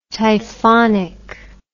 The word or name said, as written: typhonic